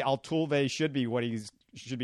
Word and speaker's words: Altuve: I guess I have to say this three syllables before we close things out Altuve, Altuve. And by the way, I, I said it right that time.